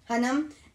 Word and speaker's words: Hunnam: And I'd also like to add Jungle Land, starring Jack O'Connell, Charlie Hunnam, I don't know how to say his Hunnam. – Name. Hunnam, and Jessica Barden.